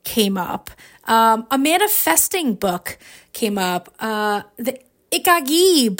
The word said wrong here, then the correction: Ikigai: book came up. Hopefully I said that right, Christina. Ikigai. Yeah. I knew I'd say it wrong, but I'm trying.